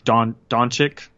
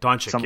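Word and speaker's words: Dončić: Doncic. – Doncic.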